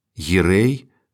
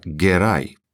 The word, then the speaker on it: Герай: В історичній літературі є дві традиції вимови родового імені кримських ханів Гірей та Герай. Гірай. Перший варіант ближчий до усталеної османської фонетичної норми, другий до кіпчакської. Вочевидь, переваги вартий другий варіант як більш властивий кримсько-татарській мові.